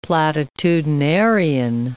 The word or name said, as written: platitudinarian